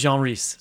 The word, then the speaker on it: Jean Rhys: I used to call her Jean Rhys, but I'm pretty sure that the actual French pronunciation is Jean Rhys. So.